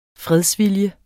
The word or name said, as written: fredsvilje